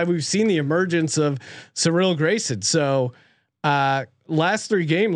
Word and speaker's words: Cyril: Cyril. – Cyril. – Cyril. – Cyril. – C-Y-R-I-L.